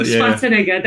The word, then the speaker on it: Schwarzenegger: Schwarzenegger, that's it.